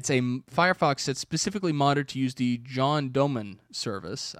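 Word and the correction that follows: JonDonym: I think I'm saying that right. John Doe, N-Y-N? – John Donim.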